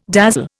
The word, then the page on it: dazzle: Indian